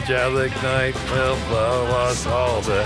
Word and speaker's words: Gelignite: Gelignite.